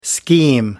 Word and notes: scheme: American English

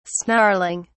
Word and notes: snarling: British English

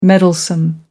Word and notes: meddlesome: American English

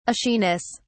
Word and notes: ashiness: British English

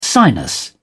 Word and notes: sinus: British English

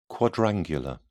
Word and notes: quadrangular: British English

